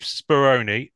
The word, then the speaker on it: Speroni: Anyway, Keith Speroni. Am I pronouncing that correctly for a start? – Nailed it.